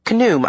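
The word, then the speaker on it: Khnum: "That'd be pronounced Knoom, I suppose. Rhymes with kaboom."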